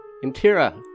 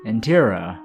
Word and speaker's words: Intira: Well, it seems like we have a standoff, Miss, what's her name? Miss Nintira? – Intira. – Intira. – R. – Could you spell that? – It's I-N-T-I-R-A.